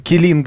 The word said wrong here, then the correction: Келин: А постпред России при ОБСЕ Андрей Келин.